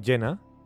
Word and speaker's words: Jenna: ¿Pero cómo es el nombre? Gina. No, Jenna. Jenna, sí, tal cual, ¿no? Se pronuncia.